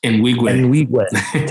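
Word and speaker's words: Nwigwe: How do I say his last name? – Enwigwe. – Toby Enwigwe. Yeah.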